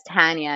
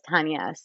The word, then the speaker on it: Tanya: But my parents actually pronounced my name differently. One says Tanya and one says Tanya. So you're not totally off. Like I hear and respond to both.